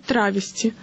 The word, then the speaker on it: травести: Поскольку речь идет о перевоплощении в женский образ, даже если молодой человек просто демонстрирует женское платье, таких артистов лучше называть «травести» с модным ударением на первый слог.